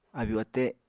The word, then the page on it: abyu-ate: Apatani